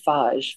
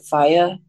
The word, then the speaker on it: Fage: I like the fage. I know how they pronounce it, faya. It's spelled FAGE, and I've talked about this before.